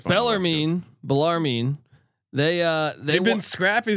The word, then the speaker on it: Bellarmine: Bellarmine. How do we say this? – Bellarmine.